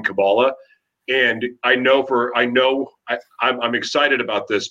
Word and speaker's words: Kabbalah: You're saying Kabbalah, right? Are you saying Kabbalah? – That's the one. So our pronunciations are slightly different.